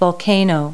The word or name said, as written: volcano